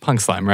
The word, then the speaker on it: PNKSLM: It is pronounced Punk Slam, right? – Oh, my gosh.